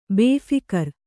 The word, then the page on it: ಬೇಫೀಕೀರ: bēphīkīra